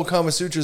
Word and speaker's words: Kama Sutra: That's what the whole Kama Sutra is about, is how to fuck like that. – Right. But I said Karma Sutra. I didn't want him to fuck with my bad pronunciation, my mispronunciation.